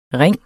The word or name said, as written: ringe